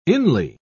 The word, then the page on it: inly: British English